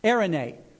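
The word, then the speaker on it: Erene (εἰρήνη): The Greek word is, pronounce this word for me. Erene.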